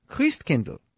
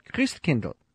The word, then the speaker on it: Christkindl: Now, how do you say Christ child in German, which, of course, was Martin Luther's language? Christkindl. That's right, Christkindl. What does that sound like? Kris Kringle. That's where the name comes from. The German pronunciation of Christ child, Christkindl, Kris Kringle.